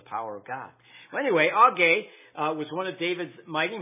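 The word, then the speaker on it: Agee: Now, you probably have pronounced it A-G. A-G-E-E, but it's pronounced Augee. And I like that better than A-G.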